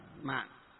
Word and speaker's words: mệnh: Nó vẫn là một cái chữ Hán ấy, nhưng ở miền Bắc chúng ta đọc là mệnh, còn ở miền Nam đọc là mạng.